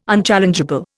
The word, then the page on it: unchallengeable: Indian